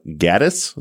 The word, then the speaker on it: Gaddis: which is the murders of Ashley Pond and Miranda Gaddis. Is that how you pronounce her last name? – Yep.